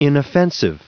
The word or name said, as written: inoffensive